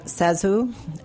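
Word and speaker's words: ZaSu: Oh, so she actually pronounces her name Zazu, which is totally counterintuitive, but she was a silent screen star who had these enormous, enormous eyes and sort of a pouty face.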